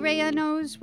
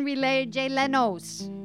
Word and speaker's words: rellenos: And although I told her to read the L's like J's, I think grandma called them something like chicken relay jelenos.